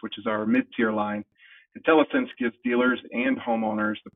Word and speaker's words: IntelliSense: I hope I pronounced that right. IntelliSense technology. – You did indeed. It is IntelliSense, and I'd be glad to take that one.